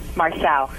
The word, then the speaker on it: Marcel: Like a battery. Cell. Marcel.